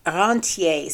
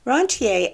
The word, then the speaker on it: rentier: The alternative to paying taxes and not paying your legitimate tax bill is illegal, is to have a rentier system. Now, I learned it with the French pronunciation, but the word is used in English, so maybe it's rentier, R-E-N-T-I-E-R.